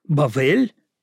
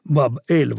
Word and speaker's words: Бавель: На древнееврейском языке это будет Бовл, Бавель. Ну, поскольку В и Б – это одна и та же буква, то часто это произносится как Баб-Эль – ворота Бога.